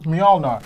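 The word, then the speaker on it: Mjölnir: Did I pronounce it? – Mjolnir. – The MJ. You know, the MJ hammer. – It's a Y sound.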